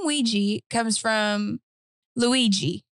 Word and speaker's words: Ouija: It is Ouija.